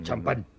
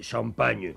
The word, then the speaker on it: champagne: No se dice champán, no, se dice champagne.